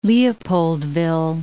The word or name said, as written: Leopoldville